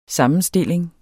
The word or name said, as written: sammenstilling